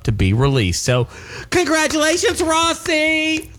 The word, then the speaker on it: Rhossi: Well, it's spelled R-H-O-S-S-I, so I could have, you know, the vowel and two consonants in between another vowel. I should have probably shortened that out to Rossi and not made Rossi. A long O, yeah.